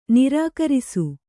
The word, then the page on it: ನಿರಾಕರಿಸು: nirākarisu